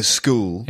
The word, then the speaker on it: scheme: And you don't say a shul, you say a school. – Yeah. – In fact, pretty much every word that begins with S-C-H in English is pronounced sk, except schedule.